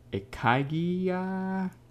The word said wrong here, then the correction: ikigai: I think it's ikigai.